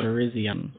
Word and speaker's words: Virizion: Vizion? Vizion? Is that how you say it? One of the Swords of Justice. – I don't even see it. – Virizion. – Oh, true. – Virizion.